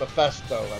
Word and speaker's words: Mephisto: Mephisto, I believe is how you pronounce it.